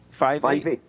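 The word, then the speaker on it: Fyvie: I'm going to say it right. How's it 5E? – 5E. Yes.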